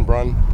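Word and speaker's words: Brun: Kevin Brunn.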